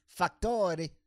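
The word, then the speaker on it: Facktoré: Ah, moi, moi mon nom, c'est Factore. – Factore. – Factore. – Factoré. OK, parfait. – Ça se dit comme ça s'écrit. – F-A-C-K-T-O-R-E, accent aigu. Factore.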